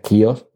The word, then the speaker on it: Chaos: Bueno, Chaos, que sabéis que se pronuncia Kios en, inglés, pero bueno, lo digo así para que, que comprendáis mejor el título.